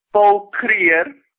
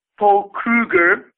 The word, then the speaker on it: Kruger: The president of the Transvaal at the time, the famous Paul Kruger, or as Americans would pronounce it, Paul Kruger, after whom the famous Kruger Rands are named, initially tried to negotiate, but with Shepstone.